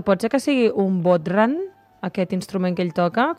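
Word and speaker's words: bodhrán: Hem anat buscant aquí instruments irlandesos i ens sortia una cosa que es deia bodhran, però potser ho pronuncio molt, molt malament. Vaja. – No, és bodhran.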